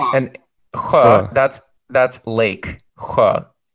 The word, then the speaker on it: sjö: It's spelled S-J and then A, which is an O with two dots over it. Sjö. So the S-J... The SJ is pronounced or and then the O is an so yeah, you got it.